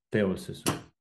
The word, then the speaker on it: теосис: Але я думаю, що теосис на перший склад.